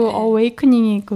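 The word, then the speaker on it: awakening: 연도 카드 16번 더 타워의 키워드는 루드 어웨이커닝 다시 이거 발음 다시 할게. – 편집은 없습니다. – 아야! – 말씀하세요. – 루드 어웨이크닝입니다. 이게 RUD가 거친 네. 어웨이크닝이 그 깨닫는다.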